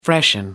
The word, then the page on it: freshen up: British English